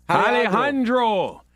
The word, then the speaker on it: Alejandro: How you pronounce it? – Alejandro. – Alejandro Mayorkas, Homeland Security head, was asked about the photo yesterday.